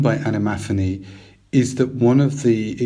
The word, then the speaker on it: animaphany: I would say animaphany.